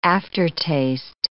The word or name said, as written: aftertaste